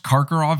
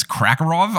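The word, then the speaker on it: Karkaroff: I also mispronounced Karkarov as Krakarov.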